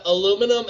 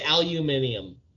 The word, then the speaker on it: aluminium: Why do people say aluminium? – That's over in England. That's how they pronounce it, aluminium. They don't say aluminum.